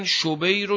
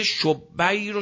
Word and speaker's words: شبیر: اشتباهن میگن شبیر و شبر، این تلفظ درسته شبیر و